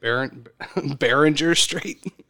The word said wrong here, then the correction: Bering Strait: The Bering Strait, the Beringer Strait, the Bering Strait.